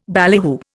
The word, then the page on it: ballyhoo: Indian